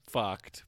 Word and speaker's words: FUCT: So the clothing line's Fucked, but it's spelled F-U-C-T.